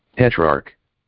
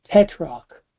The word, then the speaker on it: tetrarch: At that time, Herod, I forget how to pronounce it. Let me see if I can get a pronunciation on this. – Tetrarch. – Tetrarch. Okay.